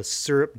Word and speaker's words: Syrp: Today for gear time, we're going to talk about the Syrp Genie. That's spelt S-Y-R-P if you're going to Google it, and uh, Genie is spelt like normal.